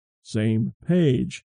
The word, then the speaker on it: SMPAG: The group will be called the Space Mission Planning and Advisory Group, or the SMPAG, pronounced, of course, same page.